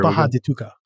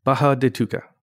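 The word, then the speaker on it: Barra da Tijuca: I think it says bar or it's you know spelled bar, but I think the Portuguese pronunciation is Baja, just kind of like Baja Mexico or something like that. – So it's there Baja de Tuca.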